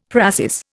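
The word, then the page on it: précis: Indian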